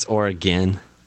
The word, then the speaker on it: Oregon: No, it's Oregon. – Oregon? – It's like or again.